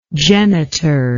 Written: genitor

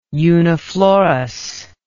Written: uniflorous

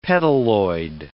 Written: petaloid

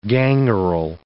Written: gangrel

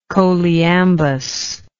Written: choliambic

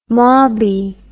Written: mauby

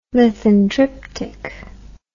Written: lithontriptic

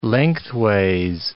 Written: lengthways

